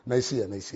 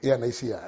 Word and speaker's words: Nicaea: 325 AD, they had this nuclear, is it? Do you see a nuclear cancer? Nuclear or Nicaea? – Nicaea. – But I think, Nicaea. I think that's the pronunciation. That's the right pronunciation.